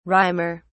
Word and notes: rimer: British English

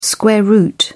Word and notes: square root: British English

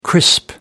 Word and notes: crisp: American English

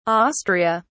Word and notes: Austria: British English

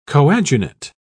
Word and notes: coadunate: British English

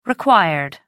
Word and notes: required: American English

